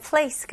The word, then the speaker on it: place: 우리나라 말로 where식으로 이제 place 뭐 이렇게 나눈데요. 영어로는 그냥 이름절로 하셔야 돼요. 그래서 여기 있는 소리들 place 연결해서 이름절 place. 그리고 마지막에 으 소리를 안 내려고 한번 노력을 해보세요.